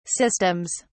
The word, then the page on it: systems: British English